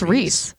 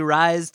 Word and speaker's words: Rhys: R-H-Y-S. – Yeah, like the guy from Americans. I think, isn't it? – That's Reese.